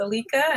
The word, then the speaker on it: Alica: And my name is Alica. It looks like Alicia on paper, but it's actually pronounced Alica, and you'll see it in the show notes.